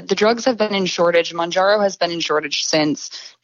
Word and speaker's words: Mounjaro: And stop me if I'm mispronouncing these, but Mounjaro and Zepbound, is that correct? – Mounjaro and Zepbound.